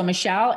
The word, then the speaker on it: Michelle: So Michelle, M-I-C-H-E-L-L-E. My middle name is Angeli, E-N-J-O-L-I, and Beato, B as in boy, E-A-T-O.